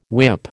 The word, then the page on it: whip: American English